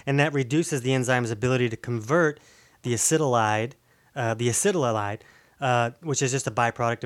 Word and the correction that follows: acetaldehyde: I keep saying that wrong because it's acetaldehyde. – Sound it out. – It's acetaldehyde.